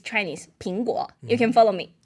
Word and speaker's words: 苹果: And why we send apple is meaning because 苹果, they have the 苹. It's the same pronunciation. It's 平安.